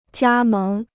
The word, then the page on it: 加盟: jiā méng